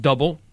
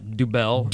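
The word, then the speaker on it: Dubbel: First of all, it is pronounced double, not dubel.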